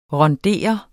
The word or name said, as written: RUNDERET